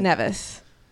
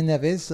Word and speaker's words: Nevis: I had been pronouncing the name of this island wrong my entire life. – Well, how have you been pronouncing it? – Nevis. – No, it all depends where you come from. You can say Nevis or well, in England, it's in Scotland. There's a mountain called Ben Nevis.